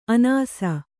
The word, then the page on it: ಅನಸು: anasu